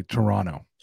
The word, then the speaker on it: Toronto: We don't pronounce the second T. We say Toronto.